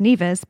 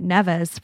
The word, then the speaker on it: Nevis: And so neither one of us like knew what Nevis was or that it was even pronounced Nevis because you've been calling it Nevis for like, you know, as long as you've known about it.